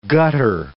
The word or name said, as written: gutter